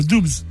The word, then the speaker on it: Doubs: Le Doubs.